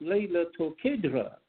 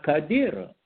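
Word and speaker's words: Laylatul Qadr: So it's pronounced Laylatul Qadra, not Qadira.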